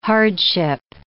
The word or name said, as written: hardship